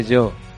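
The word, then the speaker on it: Giò: No se dice Gio, se dice Yo. – Yo. – En italiano, Gio es yo.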